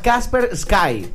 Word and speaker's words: Kaspersky: Se llama Kaspersky.